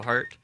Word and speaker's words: Hart: So I'm on Instagram at the Joshua Hart. – Okay. – That last name is spelled H-A-R-T.